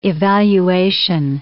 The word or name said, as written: evaluation